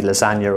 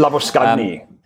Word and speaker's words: Labuschagne: I thought it might be Lasagna or something when I first saw it. So thank you for that. Actually, Labuschagne.